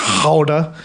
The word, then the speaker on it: Gouda: That's like learning that Gouda cheese is not pronounced Gouda. It's pronounced Gouda.